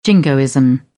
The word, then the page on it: jingoism: British English